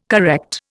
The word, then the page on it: correct: Indian